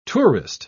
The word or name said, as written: tourist